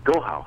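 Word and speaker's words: Gaokao: Is the Gao Cao? Is that how you say it? Like, they have this... There's this thing in China that they... – Gohao. – How do you say it? – Gohao.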